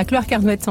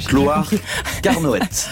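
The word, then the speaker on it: Clohars-Carnoët: À Cloire-Carnoët, en pays de Quimperlé. – C'est ça, je n'osais pas prononcer le nom de la commune. Ouais, Cloire-Carnoët, dans le pays de Quimperlé, absolument.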